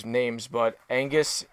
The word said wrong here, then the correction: Anguis: is Anguis.